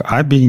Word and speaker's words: ABBYY: Сегодня к нам пришли замечательные гости из компании Аби, которая как мы теперь выяснили, оно произносится как Аби, не как Эби, не как IBE, не, не как, а просто как Аби.